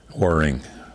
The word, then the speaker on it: Warring: Waring. – Waring. – Waring Hills. – How do you spell that? – W-A-R-R-I-N-G. – W-A-R-R-I-N-G.